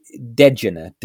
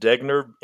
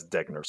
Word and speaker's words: Degner: I pronounced it Degner, and it's German, and I based that pronunciation on a doctor named Dr. Degner that I found online who pronounces her name as Degner. So I'm not claiming any specific knowledge about that. I just literally am basing it on how she pronounces it. So I've been going with Degner.